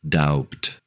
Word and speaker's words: doubt: Doubt. – Do you pronounce the B or no? – I do not doubt.